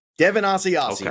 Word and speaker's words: Asiasi: Devin Asiasi. Okay. Two receptions. – Devin Asiasi.